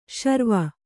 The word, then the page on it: ಸುರ್ವು: survu; Kannada